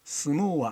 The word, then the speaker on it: 角力: 相撲は丸い土俵です。しかし角の力、角力と書いて相撲と読みます。